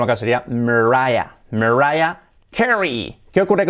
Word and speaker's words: Carey: Y las palabras que acaban en EI, pues como Disney, que decimos que realmente se dice Disney, o Sydney, la ciudad que es Sydney, pues Carey es Carey. So, Mariah Carey.